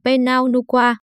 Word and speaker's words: Pinot Noir: Được phát âm là Pinot Noir, loại rượu này được làm từ nhiều loại nho đặc biệt có màu sẫm và mọc thành chùm chắc tay.